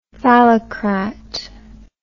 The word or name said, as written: phallocrat